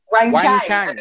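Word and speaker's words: Wang Qiang: Wang Quang, number three. – Wang Chang. – Okay, Wang sorry. Chang.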